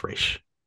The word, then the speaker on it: Ra's: So the one of those, so not exactly one-to-one, but the one that I still will never accept is the pronunciation of Ra's al Ghul in Batman Begins. It's Ra's. It's Ra's. I don't care what you say.